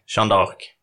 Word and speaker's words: Jean d'Arc: En fuck, marry, kill där du får välja mellan Martin Luther, Sean Ark och Gandhi. – Vänta, vem var i mitten. Chanot? – Nej. Kan du uttala det? Du är bättre. – Jean d'Arc.